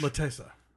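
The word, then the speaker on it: L'attesa: The new stuff this week is Piero Messina's La Lessa with Juliette Binoche. – La Tessa.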